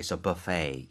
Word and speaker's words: buffet: 그래서 buffet도 스펠링이 B-U-F-F-E-T이지만 그 T를 발음하지 않아요. – 아, 오케이. So buffet.